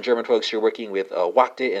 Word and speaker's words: wachten: Dutch infinitive is spelled W-A-C-H-T-E-N. German infinitive is spelled W-A-R-T-E-N. Spelled respectively, Wachte and Varten.